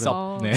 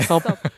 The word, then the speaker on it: 섭: 섭. – 아, 네. 네. – 우섭 하면 발음을 못 하더라고요. – 그래서 섭. 네. 섭. 서브웨이 할 때 섭이다라고 하니까.